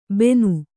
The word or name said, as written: ಬೆನು